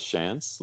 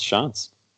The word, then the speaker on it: Shantz: Yeah, most most people pronounce it as Shantz, like just if you if you read my name, but it's pronounced Shantz.